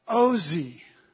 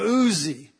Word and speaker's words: Ozi: When we see Him as our strength, that's the Jehovah Ozi. Now, if it wasn't a long O and a short O, it would be Jehovah Uzi.